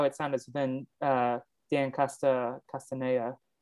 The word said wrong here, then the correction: Castellaneta: Castellaneta did the voice for that.